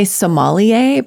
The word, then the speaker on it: sommelier: Some people say sommelier, but it's actually S-O-M-M-E-L-I-E-R. Sommelier. I think that's the French pronunciation. If I'm going back to my French, sommelier.